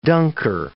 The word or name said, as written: dunker